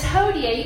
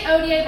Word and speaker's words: hodie: So how to say yesterday in Latin is hairy, H-E-R-I, long I. And how you say today in Latin is hodie. You can say odie, but I'm going to say hodie for the sake of this joke, but it's spelled H-O-D-I-E.